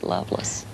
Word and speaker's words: Lovelace: I married a guy from North Carolina who, his name is spelled Lovelace, but they pronounced it Loveless.